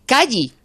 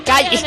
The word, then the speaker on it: Kaili: pero he oído pronunciar Calle. Calle, Calle. Calle. No, Calle calli.